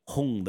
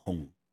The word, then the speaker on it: Hong: And which, by the way, Hong, the name, sounds exactly the same. Like the color red is also Hong. They're pronounced identically, even though it's two different Chinese characters, wow.